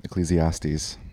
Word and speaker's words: Ecclesiastes: So Ecclesiastes, am I saying that right? – Yep. Ecclesiastes. Yeah.